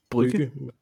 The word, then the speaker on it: Brugge: Men altså, det er fordi, det er fra Bruges, er det ikke sådan, man udtaler det? Eller Brygge. Brygge. Brygge. – Ja. Brygge. Brygge, vil jeg kalde det.